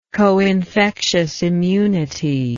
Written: coinfectious immunity

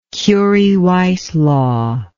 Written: curie weiss law

